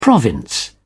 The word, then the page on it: province: British English